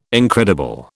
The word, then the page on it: incredible: British English